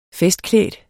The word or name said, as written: festklædt